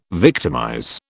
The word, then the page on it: victimize: British English